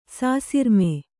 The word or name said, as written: ಸಾಸಿರ್ಮೆ